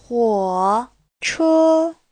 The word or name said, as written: huo3che1